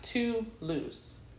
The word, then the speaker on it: Toulouse: this week's spotlight goes to If I Wanna, and it's spelled W-U-N-N-A, If I Wanna, by Toulouse featuring Sanabose. If y'all need spelling, of course, Toulouse is spelled T-O-U-L-O-U-S-E. It sounds like too loose, but it's spelled differently. And Sanabose is S-E-I-N-A-B-O-S-E-Y.